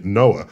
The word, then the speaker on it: NOAA: Get NOAA to do that, the National Oceanographic and Atmospheric Administration, which has the clever pronunciation, NOAA, because it monitors like floods and things like that. – Ooh, that's how you pronounce N-O-A-A.